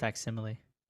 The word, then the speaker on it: facsimile: They're publishing a faux simile version of A Death in the Family. So what does that mean? – It's pronounced facsimile.